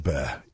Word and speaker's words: Bexar: Bexar, B-E-X-A-R, is pronounced bear.